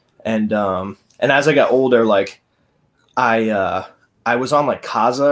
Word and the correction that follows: Kazaa: Do you mean Kazaa? – Yeah, Kazaa. Oh, is that how you say it?